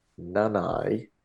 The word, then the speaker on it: Nanai: Um, just on pronunciations of names, which we talked about earlier, um, apparently Jeremiah Nanai, it's pronounced Nana, like grandma type thing.